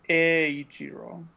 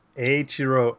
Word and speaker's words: Eiichiro: It's E-I-I. – Well, so it's Eiichiro. Because okay. The E in Japanese makes the E sound. – So it's Eiichiro Oda. – Yeah. – All right. – And it's like a long E.